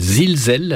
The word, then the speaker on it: Zilsel: Donc, je voudrais rappeler simplement que tu es un des animateurs d'une revue qui s'appelle Zilzel. Alors, ça s'écrit Z-I-L-S-E-L. – Z-I-L-S-E-L.